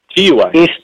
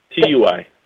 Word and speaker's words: TUI: Yes, am I saying that right? TUI. Right. TUI. TUI. – Yeah.